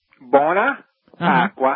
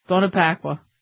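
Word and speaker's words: Buona Pasqua: Buona Pacqua. Uh-huh. – Buona Pacqua.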